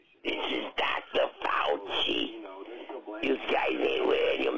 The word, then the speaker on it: Fauci: I keep forgetting it's Fauci, but I keep saying Faucho Marx.